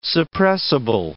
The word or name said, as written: suppressible